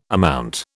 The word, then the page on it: amount: British English